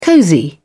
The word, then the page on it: cozy: British English